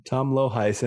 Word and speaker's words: Lohuis: Tom Loheis.